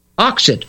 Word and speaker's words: Oxit: Oxit, O-X-I-T.